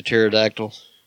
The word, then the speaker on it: Pterodactyl: PH. Silent P. It's like Terry the Pterodactyl.